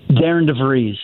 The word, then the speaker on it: DeVries: So the last coach, though, it was a Darian DeVries. Is that how you pronounce that? – Darian DeVries. – Okay.